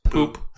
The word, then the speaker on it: Pupp: It's the Grand Hotel Pup, P-U-P-P. We learn later it's pronounced poop.